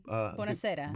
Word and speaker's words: Buonasera: Buonasera. – Buonasera.